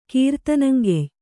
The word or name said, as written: ಕೀರ್ತನಂಗೆಯ್